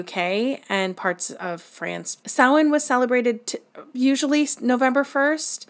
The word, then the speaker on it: Samhain: Even though it's spelled Samhain, it's Samhain, which translates directly to summer's end.